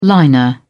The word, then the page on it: liner: British English